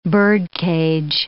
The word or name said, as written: birdcage